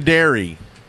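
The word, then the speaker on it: Daire: Are you sure that's pronounced right? – Yeah, I think I pronounced it right. – This is from Dairy.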